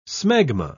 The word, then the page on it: smegma: British English